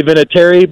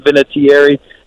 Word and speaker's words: Vinatieri: So, yeah, it's we say Vinatieri, but I've heard a lot of people pronounce it with more enunciation. So if you want to call me Adam Vinatieri, knock yourself out.